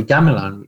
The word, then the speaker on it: gamelan: Now, the gamelan, you pronounced it right there.